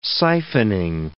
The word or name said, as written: siphoning